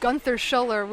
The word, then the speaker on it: Gunther Schuller: I love that he calls himself Gunther Schuller, which is the American pronunciation of a German name, but he's American.